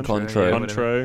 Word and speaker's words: Cointreau: Cointreau.